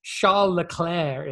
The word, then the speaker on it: Charles Leclerc: And as good as Charles Leclerc is, I probably butchered that in the French.